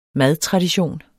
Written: madtradition

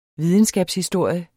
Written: videnskabshistorie

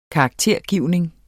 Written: karaktergivning

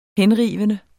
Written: henrivende

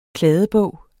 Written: kladdebog

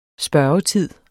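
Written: SPØRGETID

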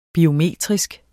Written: biometrisk

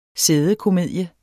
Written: sædekomedie

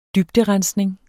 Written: dybderensning

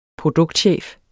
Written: produktchef

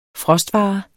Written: frostvare

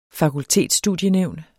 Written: fakultetsstudienævn

